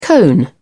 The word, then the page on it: cone: British English